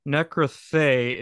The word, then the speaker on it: Nekroí: Nekroí is the correct pronunciation of that. I know it's Greek. Well, according to Reddit, it's the correct way.